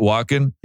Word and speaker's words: Walken: Walken? – Yeah.